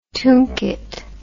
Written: tunket

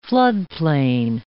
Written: floodplain

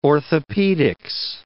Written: orthopaedics

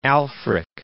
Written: Alfric

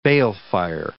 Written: balefire

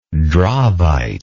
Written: dravite

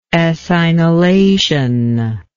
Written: ethynylation

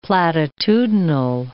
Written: platitudinal

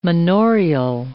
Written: manorial

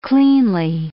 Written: cleanly

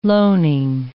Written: loaning

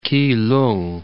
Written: Keelung

